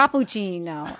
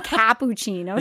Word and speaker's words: cappuccino: It's a cappuccino. It's not cappuccino. – You don't say cappuccino.